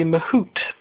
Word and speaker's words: Mahut: Of course, Mahut, it should be Mahut, but obviously you don't pronounce the T because he is French.